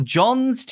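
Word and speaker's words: Gjon: Is it John's Tears? John's Tears. There's a silent G, Ben. Get with the language.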